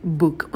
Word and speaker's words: book: И в слове «book» у нас краткий «у», проскакивайте его побыстрее, долго не сидите.